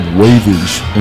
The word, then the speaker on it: WVG: I play in a project called Wavage. It's spelled WVG.